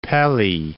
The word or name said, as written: Pelly